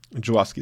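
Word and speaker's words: Żuławski: Aber ja, äh, so, so ein Film wie Possession oder generell halt, ähm, ähm, was müssen wir nicht falsch aussprechen, Juwawski? Juwawski ist richtig, ja.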